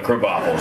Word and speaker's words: Krabappel: Spelled crabapple, but Krabappel is how you pronounce it.